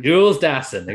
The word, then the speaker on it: Jules Dassin: Jules Dassin. Exactly. Exactly how Mark Hellinger, which I will talk about fucking Mark Hellinger. I'll talk about him shortly, but exactly how he pronounces it in the opening of the film.